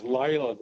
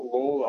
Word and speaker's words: Layla: Best-selling, and I think it's pronounced Layla, the song, not Lawler.